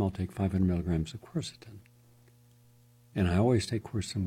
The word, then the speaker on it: quercetin: Quercetin, Q-U-E-R-C-E-T-I-N, quercetin, three syllables, easy.